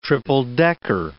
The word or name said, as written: triple-decker